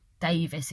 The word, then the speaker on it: Davies: Well, Davis, he says, but it's spelled Davies.